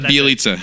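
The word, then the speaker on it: Bjelica: Would it be, well, maybe not to this level, but I know like a Aflalo threw a haymaker at Bielica. Am I saying that right? Bielica? – Yeah. – Anyway.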